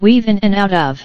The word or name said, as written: weave in and out of